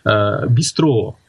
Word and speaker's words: бистро: А потом оно по-французски манер, у них же окончание на последний слог, ударение, «бистро».